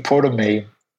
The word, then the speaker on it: Prometei: And even the word, the name Prometei was found inside the malware. And this is, of course, the Russian pronunciation for the Prometheus from Greek mythology.